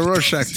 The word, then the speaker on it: Rorschach: It's like it's a Rorschach. Is that how they say it? – Rorschach drawing. – All right. Yeah, Maze. It's a Rorschach test, right? – Clip it, Maze.